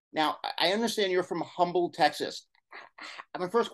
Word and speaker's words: Humble: Well, you know, the funny thing about being from Humble, you know, the H is silent, is I think for a lot of people, it was outside of Houston.